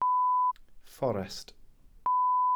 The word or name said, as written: fforest